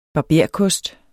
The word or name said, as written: barberkost